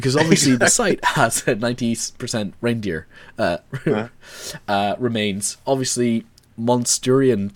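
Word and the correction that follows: Mousterian: tools. I think that's Mysterian. Really cool. What? – Mysterian.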